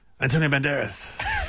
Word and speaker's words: Antonio Banderas: Antonio Banderas.